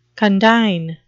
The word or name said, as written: condign